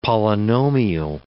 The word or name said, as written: polynomial